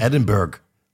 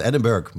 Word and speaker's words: Edinburgh: Omdat er staat gewoon Edinburgh. Er staat Edinburgh, maar je zegt Edinburgh.